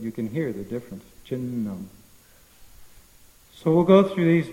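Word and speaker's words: chinnam (छिन्नम्): So when you have two consonants together, the same letter, then you have to actually pronounce both of them. It's not chinnam, it's chinnam.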